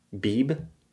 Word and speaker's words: Beebe: Beeb. B-E-E-B-E. Beeb. – B-E. – Beeb. – E-B. – E-B. Beeb. Bib. – I feel like that last Beeb. E is silent.